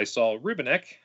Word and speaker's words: Saul Rubinek: They work at a deli together, the deli is owned by Saul Rubinek.